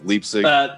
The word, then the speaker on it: Leipzig: Is I'm pronouncing that right, Ben? Leipzig.